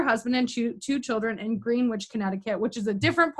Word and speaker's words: Greenwich: And by the way, just pronounce it Greenwich. – Greenwich.